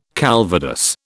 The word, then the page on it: calvados: British English